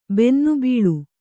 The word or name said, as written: ಬೆನ್ನುಬಲ